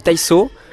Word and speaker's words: taiso: C'est quoi du taizo ? – Alors du coup ça se prononce du taiso,